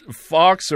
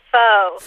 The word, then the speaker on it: faux: Is it pronounced fox or faux? – No, it is faux.